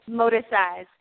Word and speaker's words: Moticise: I'm like, it's motisize. It's really, it's a hybrid motisize.